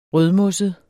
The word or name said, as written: rødmosset